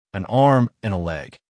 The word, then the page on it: an arm and a leg: American English